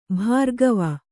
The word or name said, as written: ಭಾರ್ಗವ